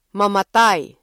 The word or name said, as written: mamatay